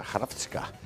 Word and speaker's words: Hrvatska: Es como se escribe Croacia en croata, Hrvatska.